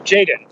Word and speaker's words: Jadon: Am I saying that right? – Jadon. – Jadon. Like Jadon.